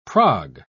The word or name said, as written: prog